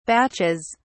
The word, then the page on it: batches: British English